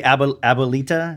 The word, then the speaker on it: Abuelita: Abuelita.